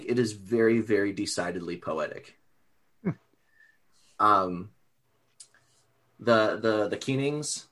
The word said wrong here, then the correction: Kennings: am I saying that right? Is it two E's? I don't remember. – Kennings. – Kennings. Two N's. Close.